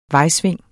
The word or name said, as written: vejsving